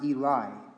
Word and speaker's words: Eloi: I know it says Eloi, but it would have been pronounced Eli.